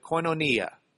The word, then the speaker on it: koinonia: That word fellowship in the Greek, it's the Greek word koinonia. Koinonia, excuse me, I just changed the gender of it. K-O-I-N, long O-N-I-A. Whoa, that did you no good. Koinonia.